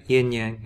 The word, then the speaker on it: yin-yang: And this yin-yang, am I saying that right? – Yeah, yin-yang, yeah.